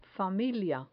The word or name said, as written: familia